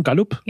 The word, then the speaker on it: Gallup: Und das ist eine Studie von Gallup, wird das so ausgesprochen? Gallup? – Ja.